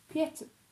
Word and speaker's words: Pieter: Oh, I've been pronouncing it wrong. So, Pieter. That's what it sounds like.